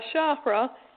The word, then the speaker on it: chakra: A chakra, and I was told by someone from India that it's pronounced chakra, where you roll the R, but I haven't been trained in my Western dialogue to dialect to pronounce roll that R.